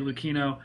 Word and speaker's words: Lucchino: You know, the new ownership headed by Larry Lucchino, and is that how you say it? – Lucchino. – You know, it's tricky because he has both the two C's and the H in there.